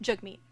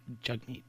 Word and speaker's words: Jagmeet: Jagmeet. – Jagmeet.